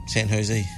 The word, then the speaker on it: San Jose: San Jose.